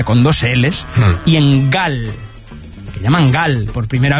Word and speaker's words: Gal: sino Gal, que en portugués de Brasil se pronuncia igual, Gau.